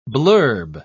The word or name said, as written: blurb